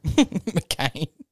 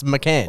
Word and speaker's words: McCann: McCain. – That's her. She's saying it wrong. She's like, tell me I'm not Maddie McCain. And they're like, well, it's McCann.